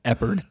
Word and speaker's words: Eppard: It's a very simple Eppard. Two syllables.